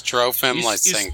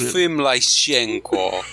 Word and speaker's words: Trofim Lysenko: That's how you pronounce it in English, but in Russian it's Trofim Lysenko. – Is Toblerone or Lysenko? – Trofim Lysenko.